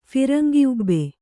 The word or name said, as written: ಫಿರಂಗಿಉಬ್ಬೆ